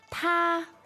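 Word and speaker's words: Ta (他): Ta.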